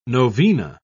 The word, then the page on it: novena: British English